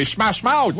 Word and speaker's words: Smash Mouth: C'est Smash Mouth.